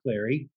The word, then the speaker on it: Clary: And I'm here with Scott Clary.